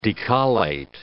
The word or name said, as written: decollate